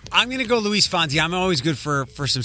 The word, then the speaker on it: Luis Fonsi: Luis Fonsi. – I always say it wrong. Luis Fonsi? – Yeah, Luis Fonsi.